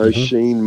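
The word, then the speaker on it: Oisín: How do you say the name of the new Irish player at Geelong? – O'Sheen. – Spelled O'Sheen? – O'Sheen. O'Sheen. – O'Sheen. – O'Sheen Mullen, is it?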